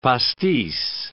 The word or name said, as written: pastis